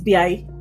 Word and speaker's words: Biyai: So my name is B.I. It is spelled B-I-Y-A-I.